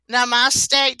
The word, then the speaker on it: namaste: Well, good morning, good afternoon, good evening, and to our listeners in India, to our listeners in India, we say namaste.